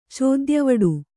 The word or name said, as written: ಚೋದ್ಯವಡು